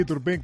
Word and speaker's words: Turpin: ¿Lo digo bien, Pedro Martín? – Bueno, tú sabrás. Sí, Turpin, que de momento es el árbitro con el que el Madrid ha conseguido mejores resultados en la historia de la Copa Europa.